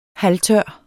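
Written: HALVTØR

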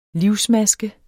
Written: livsmaske

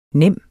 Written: nem

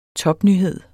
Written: topnyhed

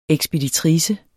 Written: ekspeditrice

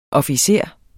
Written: OFFICERERNE

